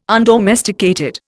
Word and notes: undomesticated: Indian

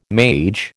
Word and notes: mage: American English

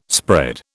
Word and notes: spread: British English

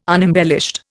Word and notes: unembellished: Indian

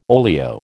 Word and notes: olio: American English

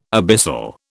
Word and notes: abyssal: British English

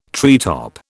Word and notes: treetop: British English

